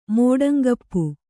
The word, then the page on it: ಮೋಡಂಗಪ್ಪು: kannada